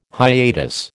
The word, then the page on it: hiatus: American English